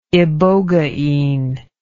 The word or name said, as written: ibogaine